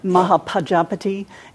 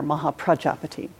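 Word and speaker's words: Mahāpajāpatī: And you'll hear me say her name both in Pali, the Mahapajapati, and sometimes in Sanskrit with the R in there, Mahapajapati.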